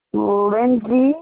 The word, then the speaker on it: Lyndsay: Lindsay, and that's spelled LYNDSAY.